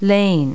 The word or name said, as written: lane